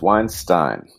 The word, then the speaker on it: Weinstein: Is it Weinstein or Weinstein? – It is Weinstein. – Okay, that's fantastic, because that means that when I mentioned it in our last podcast, I pronounced it right. – Excellent. Most people pronounce it incorrectly, but it is definitely Weinstein. – Yeah, it's the school German that I did making me want to pronounce both of the EIs the same.